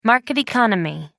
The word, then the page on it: market economy: British English